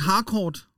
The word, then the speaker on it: Harcourt: Er det, var det rigtigt udtalt? – Har, eller? er det ikke Harcourt? – Åh, oh, det er det nok.